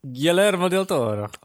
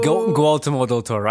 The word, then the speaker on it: Guillermo del Toro: Guillermo del Toro. – He said it right. – Oh. – Guillermo del Toro.